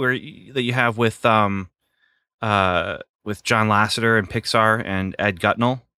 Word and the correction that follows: Catmull: Did I say his name wrong? – Ed Catmull.